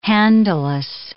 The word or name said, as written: handleless